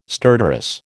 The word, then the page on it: stertorous: American English